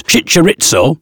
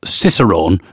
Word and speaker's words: Cicerone: Chincharitzo, or Cicerone, as it actually is, but my screen reader, I'm blind, and my screen reader is pronouncing it Chincharitzo.